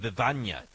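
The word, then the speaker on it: Bevagna: I want to know about the birds of Bivagna. Did I say this correctly? – Oh, yes, you did. Well pronounced.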